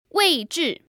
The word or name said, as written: weizhi4